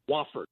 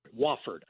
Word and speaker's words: Wofford: So when I got them this year, the first thing that I remembered was that I pounded it into my head, the pronunciation. W-A-H-F-E-R-D. Wofford. Wofford.